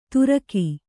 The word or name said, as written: ತುರಕಿ